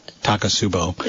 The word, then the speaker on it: Takotsubo: I pronounce it wrong. – Takotsubo. – Takotsubo, yeah. Which is Japanese.